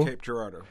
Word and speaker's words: Cape Girardeau: Juan, do you know how to say the name of this town? Is it Cape Girardeau? – Cape Girardeau. – Cape Girardeau in Missouri.